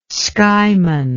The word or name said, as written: skyman